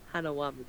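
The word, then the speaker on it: Hanauma: Hanawama.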